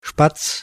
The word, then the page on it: Spatz: /ʃpats/